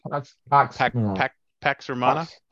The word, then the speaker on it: Pax Romana: Go back to the Pax Romana. Am I saying it right? – Pax Romana. Pax.